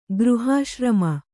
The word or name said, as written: ಗೃಹಾಶ್ರಮ